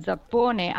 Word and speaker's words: Zappone: Invece poi l'ha convinto, ma devo dire che io non lo conoscevo, David Zappone, anzi noi diciamo Zappone ma sarebbe Zappon,